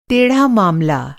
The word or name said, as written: टेढ़ा